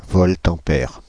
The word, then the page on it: voltampère: France (Île-de-France); /vɔlt.ɑ̃.pɛʁ/